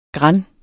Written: grand

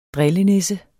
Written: drillenisse

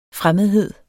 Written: fremmedhed

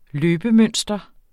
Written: løbemønster

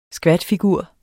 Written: skvatfigur

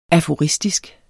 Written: aforistisk

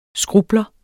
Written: skrupler

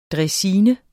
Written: draisine

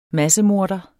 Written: massemorder

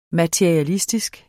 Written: MATERIALISTISKE

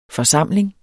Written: forsamling

